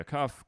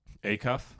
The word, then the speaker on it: Acuff: Fellow Grand Ole Opry star Roy Acuff commented, Acuff? Acuff. I said that twice now, eh? – Yeah. – Acuff? – Acuff. – Fucking me and my pronunciation. – The wrong emphasis on the wrong syllable.